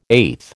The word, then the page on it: eighth: American English